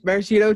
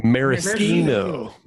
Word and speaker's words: maraschino: Or I'm sorry, what is the proper pronunciation of that? – Maraschino. – Maraschino, that's right. Because I am backwards trash if I say maraschino.